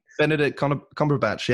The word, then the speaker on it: Cumberbatch: Yeah, I said Benedict Cumberbatch. Is that how you say his name? – Benedict Cumberbatch.